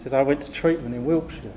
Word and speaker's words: Wiltshire: I said, no, Wiltshire. So that's why, from that day forward, I've always said, like a BBC proper pronunciation, Wiltshire.